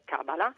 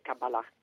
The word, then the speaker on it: Kabbalah: Nell'italiano dell'uso l'accentuazione riconosciuta, normata, è Kabbalah, e l'ambiguità nasce dal fatto che nella trascrizione, nel passaggio dal sillabario, perché l'ebraico è una lingua che si scrive con caratteri non alfabetici ma con sillabe, sull'ultima A è riportato un accento e questo ha indotto a una pronuncia anche Kabbalah.